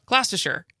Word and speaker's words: Gloucestershire: Did I pronounce it right? – Gloucestershire. – Now I got it. – That's better.